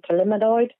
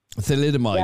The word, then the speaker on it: thalidomide: are do you pronounce that condition? Thalidomide. – Thalidomide.